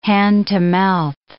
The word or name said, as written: hand-to-mouth